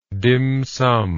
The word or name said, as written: dimsum